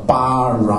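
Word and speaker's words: bara (ברא): 从右边写到左边，这个第一个字下面有一个一半的，那个是 OK，字上面的字母都是子音，下面标音的那是母音啊，所以第一个是念 B 的八，第二个字叫 R，念 R 的 Ra，后面那个不发音叫 Bara 啊，或者应该是一般希伯来文。重音都在后面，叫 bara 啊，所以叫 bara。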